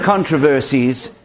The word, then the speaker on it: controversies: One of the biggest controversies, to use an American pronunciation of the word, one of the biggest controversies in throughout the history of the church has been this Calvinism versus Arminian whole thing.